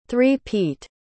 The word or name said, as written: three-peat